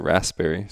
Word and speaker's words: raspberry: I don't say the P, I just say raspberry. – Well, there's a P. – I know, but I'm pretty sure raspberry. It's a silent P.